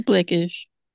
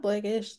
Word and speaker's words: Black-ish: Not Black-ish, like the TV show? – Like, no, but No, that show was called Blackish. It was Black-ish. – it's a different emphasis on another syllable.